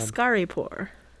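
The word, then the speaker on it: Askaripour: Ascaripor? Did I say that right, do you think? – I think you did a great job. – Ascaripor. That's the one.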